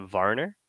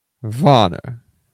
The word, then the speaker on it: Werner: And then we had, Ivan Prozdotov in net both nights, and it was Adam, it looks like Werner. – Yeah. – But you pronounce it Varner? – Varner.